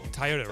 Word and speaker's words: Toyota: I like how you just said it. – Toyota, right? Toyota. I did say it wrong.